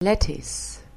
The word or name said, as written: lettuce